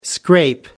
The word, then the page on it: scrape by: British English